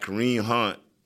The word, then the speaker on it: Kareem Hunt: You got Kareem Hunt.